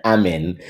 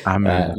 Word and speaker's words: Amin: It's Amin. Amin.